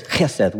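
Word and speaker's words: chesed (חסד): The word love there is chesed, which if you say it right, you apologize to people you just spit on.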